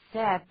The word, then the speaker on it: said: Easier said than done. Easier said than done. 请注意 said 的发音 ,S-A-I-D. 你看到这个 A-I, 可能会想要把它念成 said, 可是这是错的，这是例外字。 AI 通常念 A，可是在这里要念作 E。